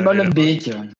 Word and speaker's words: Molenbeek: Bah, c'est Molenbeek. – Voilà. – voilà. Parce qu'il y, a deux, il y a deux E à la fin, c'est Beek.